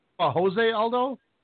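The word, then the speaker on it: José Aldo: Jose Aldo? – No, that's what I said. Jozy Aldo. – Oh, Jozy. He doesn't pronounce the Hispanic names.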